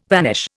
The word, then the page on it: vanish: Indian